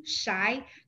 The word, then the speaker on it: Shi: So you can find me on LinkedIn, Ashley Shy, my last name, S-H-I, pronounced as a shy, and I'm not shy.